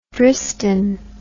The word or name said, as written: Dristan